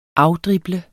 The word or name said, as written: afdrible